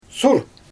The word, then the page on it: sur: Xyzyl